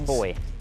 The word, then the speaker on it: buoy: Boy. – Correct, and spell it. – B-U-O-Y.